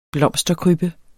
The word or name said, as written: blomsterkrybbe